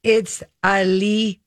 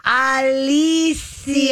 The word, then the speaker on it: Alicia: Alicia. – Yeah, I would say Alicia. – You would? – Yeah, Alicia. – Alicia. – Mm-hmm. – It's Alicia. – Instead of a Shia, it's a Sia.